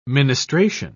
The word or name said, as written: ministration